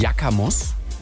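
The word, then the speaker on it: yakamoz: Das heißt, es ist Yakamos. Yakamos, kurz ausgesprochen, ist türkisch und ist das Wort für dieses silbrige Schimmern von Mondlicht auf einer Wasseroberfläche. Wie geil, dass es dafür ein Wort gibt. Yakamos.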